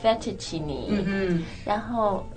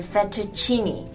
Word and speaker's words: fettuccine: And also the C, it's the ch sound. Chis. 不是 fettuccine. – Yes. – Or fettuccine. – Yes. – 是 fettuccine.